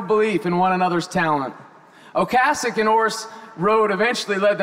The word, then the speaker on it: Ocasek: Rick Ocasek, not Ocasek, you're all saying it wrong.